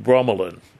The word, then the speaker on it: bromelain: Now, what I'm going to say now is if you have not used a preparation called bromelain. Now, I'll spell it. Okay, I'll spell it for you. B-R-O-M-E-L-A-I-N, bromelain.